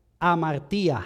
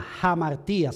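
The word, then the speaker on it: jamartía (ἁμαρτία): La palabra de Dios, cuando fue escrita en el original en griego, usa la palabra amartía, jamartía se pronuncia.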